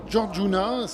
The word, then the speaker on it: Georgiouna: On va revenir sur la dernière création de Casu des Tirs, le second album Georgiouna. Je ne sais pas si je prononce bien déjà. – Oui, c'est ça, c'est Georgiouna.